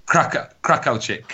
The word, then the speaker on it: Krakowczyk: I won't mind, but I've even asked Rob how to pronounce that one, and he sent it me earlier, and I've still struggling with the Polish. – Yeah, Krakowczyk. There we go.